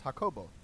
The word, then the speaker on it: Jacobo: That any Spanish speaking person whose name is Jacob, is the pronunciation is Jacobo.